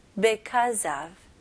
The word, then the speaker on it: because of: 네, 여기에서 be로 시작하니까 because 이렇게 발음하시는 분들이 있는데 물론 그것도 되게 정확한 발음이지만 원어민들은 그렇게 발음하기 조금 힘드니까 오히려 be보다는 be, because of, because of, because of. 네, 이런 식으로 발음합니다.